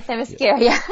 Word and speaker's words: Themyscira: Yeah, Themyscira. – Themyscira.